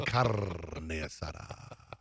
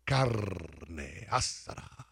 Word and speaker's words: carne asada: I'd like some carne asada. – No, you say perro. – Carne asada. Carne asada. – You put the syllable on the wrong emphasis.